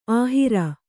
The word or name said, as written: ಅಹೇರು